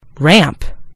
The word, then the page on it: ramp: American English